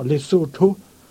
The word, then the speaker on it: Lesotho: Et ça a été un témoignage qui aujourd'hui est rayonnant à travers toute l'Afrique du Sud, le pays du Lesotho, pour bien prononcer le nom, Lesotho,